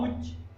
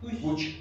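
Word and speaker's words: Puig: La J se pronuncia como Che. – No. Puch. La I, G, como Carlos. – En final de palabra se pronuncia como Che. Entonces, Puch.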